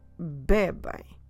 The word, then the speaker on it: bebem: So stress the be, which is the second to last syllable, bebem. And then in the end, we do the sound ain. Make sure you keep your mouth open. Don't close your lips in the end. Bebem.